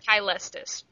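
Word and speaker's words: Caelestis: It's actually supposed to be a hard C, but like I said, it doesn't matter. I pronounce it Kylestis, but...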